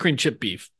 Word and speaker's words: creamed chipped beef: Did you guys say creamed chipped beef, cream chip beef? Like, yeah. When you first think of this, how do you say it? – Definitely no ED in creamed. Cream, I think.